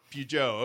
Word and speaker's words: Peugeot: Peugeot, okay.